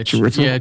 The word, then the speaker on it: Chiwetel: Joss Whedon said, Chiwetel? I don't know how you say his name. – Yeah, Chiwetel.